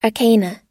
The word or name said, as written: arcana